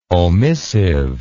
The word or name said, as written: omissive